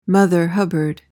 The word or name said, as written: mother hubbard